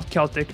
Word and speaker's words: Celtic: Celtic. – It's a hard C. It's a hard C when Celtic.